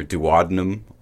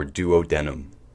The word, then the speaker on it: duodenum: This organ is commonly pronounced two ways, either duodenum or duodenum.